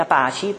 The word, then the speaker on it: Apache: È scritto Apache, però si legge Apache perché era una patch, cioè un programma informatico che serve a gestire il rapporto fra client e server.